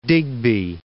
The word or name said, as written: Digby